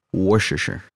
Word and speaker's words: Worcestershire: Whoever used Worcestershire sauce instead of embalming fluid, hey, evil. – You said it right. Damn you. – Worcestershire.